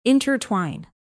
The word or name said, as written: intertwine